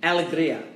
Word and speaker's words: Alegria: So the name of the new choir that we just listened to, San Antonio's new women's choir, is called Alegria. – Mm-hmm. Alegria. – Alegria. – Yes, you got it.